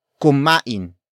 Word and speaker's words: kumain: For example, kumain tayo dito. Kumain tayo dito. Kumain tayo dito. It means, let's eat here, or we ate here, depending on the context. Word for word, it's kumain tayo dito.